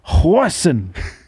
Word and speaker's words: Goosen: He said you pronounce it Retief Hoisin.